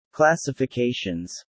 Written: classifications

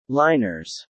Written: liners

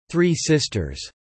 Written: three sisters

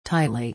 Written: tightly

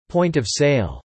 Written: point-of-sale

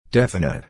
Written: definite

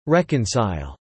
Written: reconcile

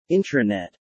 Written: Intranet

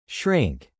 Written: shrink

